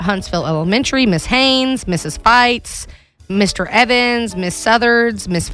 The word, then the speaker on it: Fite: Feitz. F-I-T-E. Oh. Mrs. Feitz, first grade class. – I was picturing everybody gets in there and they just fight. – No, it's pronounced like fight.